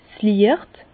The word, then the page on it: 's Liecht: Bas Rhin; Herrlisheim